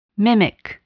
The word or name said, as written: mimic